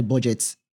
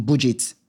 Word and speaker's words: BudgIT: That's when we turned to Tolu Tokpe Agunloye from Budget, that is spelled as B-U-D-G-I-T.